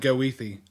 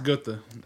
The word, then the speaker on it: Goethe: On paper, it looks like Goethe, but it's pronounced Goethe.